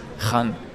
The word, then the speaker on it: Khan: Khan. Kill-Laut, oder wie? Rachenlaut. – Rachenbetonung, Rachenlaut, genau.